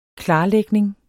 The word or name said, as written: klarlægning